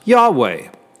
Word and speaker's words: Yahweh: In Hebrew, it's pronounced Yahweh.